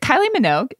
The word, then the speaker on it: Kylie Minogue: And is it Kylie Minogue? Is that how you pronounce her name? – Yeah.